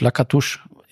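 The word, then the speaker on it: Lăcătuș: Also ich erinnere mich zum Beispiel noch an den 90er-Spieler von Rumänien, ausgesprochen Marius Lakatusch, ist jetzt nicht so, aber geschrieben Marius Lakatus, fand ich sehr cool.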